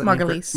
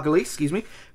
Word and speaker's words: Margulies: where he meets Lynn Margulies. Courtney Love. Sorry, I can't pronounce that Margulies. – Name. Margulies.